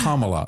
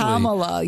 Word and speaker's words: Kamala: By the way, it's Kamala. Oh, you said it right, actually. – Kamala.